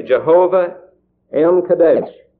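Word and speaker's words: Jehovah M'keddesh: but I'm sure it would be pronounced this way, Jehovah M. Kadesh. Jehovah, and then you have an M, and then an apostrophe, and then K-E-D-D-E-S-H, Jehovah M.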